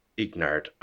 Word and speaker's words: Ignard: So A-N-A-I-S. – I-S. – Ignard. I-G-N-A-R-D.